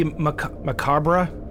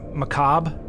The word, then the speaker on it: macabre: It can be macabre or macabre, I think.